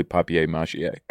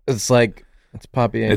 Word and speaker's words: papier-mâché: No way has anyone ever pronounced it like. – It's French. It's like a French word. – Yeah. – Papier-mâché. – Paper mâché. Sorry, I'll pronounce it your way. – Let me get it down to Sarah Squirm style. – Paper mâché. – It's papier-mâché. – It's papier-mâché.